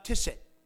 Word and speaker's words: tzitzit: And behold, a woman which was diseased with an issue of blood 12 years came behind him and touched the tzitzit. Tzitzit. T Z I T Z I T in English letters, the Hebrew word. It looks like zitzit. Put a T in front of it, and it's pronounced tzitzit.